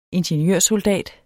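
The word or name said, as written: INGENIØRSOLDAT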